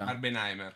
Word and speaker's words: Barbenheimer: Barbenheimer, come si pronuncia? – Barbenheimer.